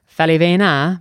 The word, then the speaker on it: Faliveno: So, you know, the Italian last name should be pronounced Falivena, but of course, when my grandparents came to the States from Naples, they decided to pronounce it Faliveno.